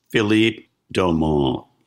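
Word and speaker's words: Philippe Dauman: Philippe Daumont. – Yes. So he was originally French, and so and he used the French pronunciation.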